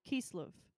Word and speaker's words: Kislev: Kislev. – Kislev? – Kislev. – Okay. – Like Kislev and coffee. – I love that. So Hanukkah always begins on the 25th day of Kislev, which is the ninth month in the Jewish calendar.